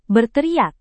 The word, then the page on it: berteriak: Indonesian